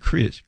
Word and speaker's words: Kreis: Pete Chris. His last name is spelled K-R-E-I-S.